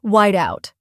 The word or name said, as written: white-out